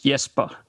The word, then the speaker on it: Jesper: Well, in Danish, it is Jesper.